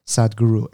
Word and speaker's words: Sadhguru: His name is, I don't even know if I'm pronouncing right, Sadhguru. – S-A-D-H-A-U-R-U? – No. Sadhguru. S-A-D-H-G-U-R-U.